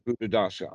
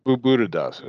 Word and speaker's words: Buddhadasa: B, is it Bhikkhu Buddha Hasa? Am I pronouncing that right? He, yeah, Bhikkhu Buddhadasa. Uh huh. Buddhadasa. There we go.